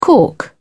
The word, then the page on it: cork: British English